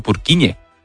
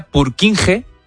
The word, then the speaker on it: Purkinje: que es el efecto Purkinje. – Purkinje. Parece ayer estuve como gallego, ¿no? – Es que se escribe, en español sería Purkinje. – Purkinje. – Pero Purkinje es en checo,